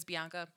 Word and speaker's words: Bianca: My name is Bianca.